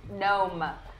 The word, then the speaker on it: Gnome: I'm Gnome. – Silent G? – Yes.